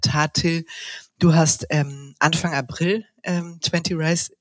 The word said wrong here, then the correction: 20 Rising: gegründet. 20 Rising, ne? Ich habe es falsch gesagt.